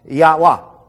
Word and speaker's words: Yahweh: So what we'll do is we'll go, okay, J-E-H-O-V-A-H, Jehovah. See how it works? But we don't know these to be accurate. And when you pronounce it in Hebrew, it simply comes out Yahweh.